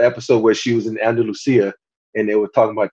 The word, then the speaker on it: Andalusia: I don't want to Andalusia.